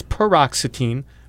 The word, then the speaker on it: paroxetine: And 57 is paroxetine, rhymes with fluoxetine.